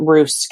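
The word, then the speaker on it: Roose: So it's the Times columnist, I think his name's pronounced Roos, Kevin Roos.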